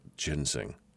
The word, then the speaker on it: ginseng: Ginsing. What did I say? Ginsing? Yeah, ginseng. Sorry. My bad. Not enough coffee this morning. It's pronounced ginseng.